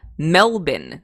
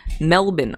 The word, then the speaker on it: Melbourne: Cheers from, and this is how they pronounce it in Australia, Melbourne, not Melbourne. Melbourne, Australia.